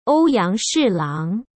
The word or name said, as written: Ouyang Shilang